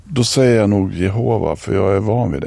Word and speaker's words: Jehova: Du, hur uttalas det förresten? Jehova eller Jehova? – Ja, vittnen säger Jehova.